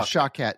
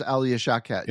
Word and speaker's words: Shawkat: Uh, Anton Yelchin, Imogen Poots, Patrick Stewart is in this film, and my uh, I don't know how to pronounce her name, Alia Shakwat, Shawkat, yeah, Shawkat. – It's Alia Shawkat.